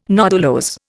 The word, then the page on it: nodulose: Indian